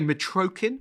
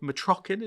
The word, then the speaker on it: Mitrokhin: Some people say Mitrokhin, but I've been told Mitrokhin is the better version of it.